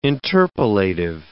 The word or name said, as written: interpolative